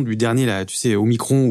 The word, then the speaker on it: Omicron: Ils sont formels il faut dire Omicron pour une raison simple, expliquée, c'est comme epsilon. Voilà. En grec, euh, quand un mot se termine par on, on prononce on, Omicron.